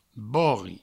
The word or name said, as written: bori